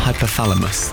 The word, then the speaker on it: hypothalamus: I didn't like how you said it, though. What did I say? Hippopotamus. Hypothalamus. There you go.